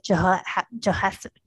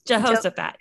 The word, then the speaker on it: Jehoshaphat: And I'm reminded of the story of Jehoshaphat. I'm not saying it right. Help me out, girl. – No, Jehoshaphat.